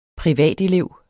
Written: privatelev

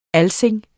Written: alsing

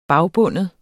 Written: bagbinde

